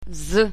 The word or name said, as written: z